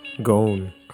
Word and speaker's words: Ghosn: Pronounce his name for me. Carlos. – Ghosn. – Carlos Ghosn. Spelled G-H-O-S-N.